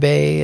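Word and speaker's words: Bae: Kevin Bay is my handle.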